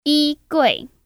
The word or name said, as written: YiGui